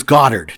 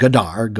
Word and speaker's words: Goddard: And it was Goddard, not Goddard, Goddard, but it's spelled right. The exact same way.